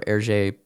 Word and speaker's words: Hergé: So, before he lands on his iconic Hergé pseudonym. He was using the previously mentioned pseudonyms. He created the pseudonym for Hergé by flipping his initials and then using the French pronunciation for them.